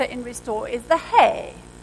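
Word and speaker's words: hey (ה): Now, you pronounce that little funny digit as hey.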